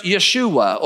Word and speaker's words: Yeshua: That was a pretty typical Hebrew name for young men, because it was a name followed after Israel's greatest hero, somebody whose name was pronounced Yeshua, or we pronounce it in English, Joshua.